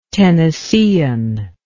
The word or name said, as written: tennessean